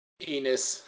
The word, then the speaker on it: Enes: Enes. – It rhymes with penis.